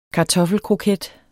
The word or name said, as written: kartoffelkroket